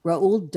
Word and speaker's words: Roald: It's like Ronald without the N. Roald Dahl, D-A-H-L.